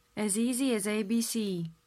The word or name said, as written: as easy as abc